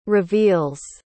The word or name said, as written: reveals